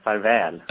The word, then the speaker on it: farvel: Now, am I even close to pronouncing it correctly when I say Et Sista Farval? – Yes, except the last word, farvel. – Oh, I see. – The farvel is the word.